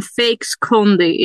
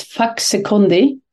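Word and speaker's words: Faxe Kondi: How much Swedish kronor have you spent on fake Kondi in your lifetime? And what is fake Kondi? – Okay, let's pronounce it right. It's Faxekondi, and it's a Danish soda.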